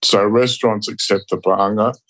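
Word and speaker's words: Paanga (paʻanga): And when you're in Tonga, because you do have a local currency, is it pronounced the Paanga? – Paanga, exactly. Perfect.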